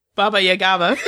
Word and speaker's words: Baba Yaga: But Kenzie being on Bo's side had consequences when she did the whole Baba Yaga thing, or whatever. I know I'm saying it wrong. But Baba Yaga thing. I know I added an extra syllable in there.